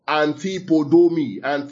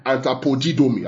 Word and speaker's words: antapodidomi: The word there, recompense, is the Hebrew word that actually spells this, antipodomi. I'll spell it for us. Yeah, that's the Hebrew word. Antapodidomi, something like that, yeah? Antapodidomi, I'll spell it for us. Yeah, it's spelled this way, A-N-T-A-P-O-D-I-D-O-M-I.